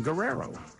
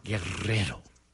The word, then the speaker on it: Guerrero: I'm Dan Guerrero, or if you can roll your R's, Guerrero.